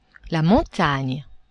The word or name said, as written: montagne